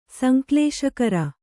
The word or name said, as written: ಸಂಕ್ಲೇಶಕರ